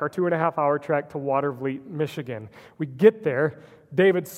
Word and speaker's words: Watervliet: We kept pronouncing it Watervliet, but I think we were so tired that we didn't see the order of the letters in the word. It's actually pronounced Watervliet, in case you ever need to know this.